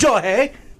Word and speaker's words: Shohei: That's what, dude, you pronounce it with it like a J. Is it Shohei? – So Shohei?